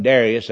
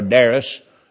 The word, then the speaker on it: Darius: I reckon Darius, that's the way Southerners pronounce it, or Darius.